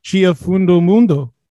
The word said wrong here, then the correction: Dia Fim do Mundo: Is that correct? – Dia Fim do Mundo.